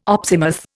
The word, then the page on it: opsimath: Indian